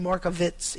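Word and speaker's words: Markievicz: So, and I thought of that because we were talking about Lisa Dell, the ancestral home of Constance, and I think she pronounced her name Markovitz, even though it's spelled with a W.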